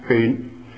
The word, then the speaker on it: Scone: Carried away by King Edward I in 1296, the 400-pound stone of Scone, pronounced Scone, reportedly was the coronation seat of ancient kings of Scotland and Ireland, and some say the pillow of the patriarch Jacob.